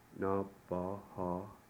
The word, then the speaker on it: Nabaha: This one has 3 A vowels. They all have the same sound, A. Nabaha.